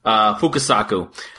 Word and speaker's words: Fukasaku: Next up was a 1968 film directed by Kinji Fukasaki, or Fukasaku, I think is how you say it. Fukasaku.